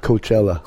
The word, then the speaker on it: Coachella: Coachella.